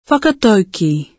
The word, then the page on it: whakataukī: Te reo Māori